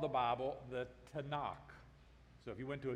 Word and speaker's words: Tanakh: "You're talking about the Tanakh." So it's just T-N-K with a couple of A's stuck in there to be able to pronounce the word, the Tanakh.